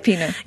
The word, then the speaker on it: Rapinoe: Rapinoe.